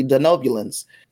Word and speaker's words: Denobulans: but I am going to say as about the Denobulans. I said it right.